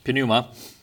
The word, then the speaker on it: pneuma: You pronounce the P in the Greek, pneuma.